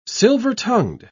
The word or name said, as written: silver-tongued